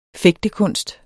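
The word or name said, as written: fægtekunst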